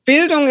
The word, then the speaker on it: Bildung: Yeah, Bildung, super.